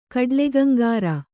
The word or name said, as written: ಕಡ್ಲೆಗಂಗಾರ